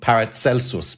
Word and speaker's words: Paracelsus: Well, actually, so the next stop on the U-Bahn from where I live is called Paracelsus Bad. – Okay, all right. – So it's pronounced in German Paracelsus. – Okay. – But that's because they pronounce Caesar's S.